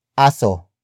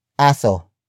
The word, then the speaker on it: aso: And that focus is aso. Aso. Aso. Aso is spelled as A-S-O. It is the Tagalog word for dog.